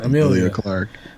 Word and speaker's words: Emilia Clarke: How do you say her name? From Emilia Clarke.